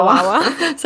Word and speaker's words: Wawa: Wawa? – Yeah, Wawa.